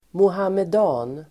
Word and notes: muhammedan: [mohamed'a:n]

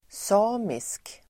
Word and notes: samisk: [s'a:misk]

